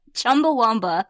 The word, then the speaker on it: Chumbawamba: I can't even pronounce this. Is it Chumbawamba? – Yes. Chumbawamba.